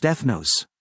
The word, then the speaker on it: ethnos: In New Testament Greek, nation is translated from ethnos, which is pronounced ethnos.